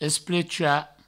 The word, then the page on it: esplechar: provençal; esplecha